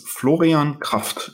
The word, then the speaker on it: Florian Kraft: Sure, like it's Florian Kraft, if you want to be super correct about the pronunciation in German. Florian or Florian in French is also perfectly fine.